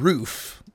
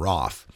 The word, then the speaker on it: Roth: Now, the shooter, Dylan Roth is his name, and everyone's calling him Dylan Roof, but if you've looked at some of the news reports a little deeper, you'll see that they've indicated that it rhymes with cough.